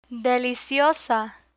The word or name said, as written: deliciosa